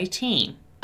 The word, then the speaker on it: team: Attention here to the pronunciation of the word team, right? Once again, we have the letters E and A together, indicating a long E sound, right? So team, team, team. If you say Tim, it's the name Tim, right? So team, T-E-A-M, is team, team, team.